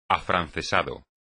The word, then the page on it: afrancesado: espagnol